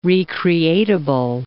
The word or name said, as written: re-creatable